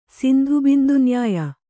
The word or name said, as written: ಸಿಂಧುಬಿಂದುನ್ಯಾಯ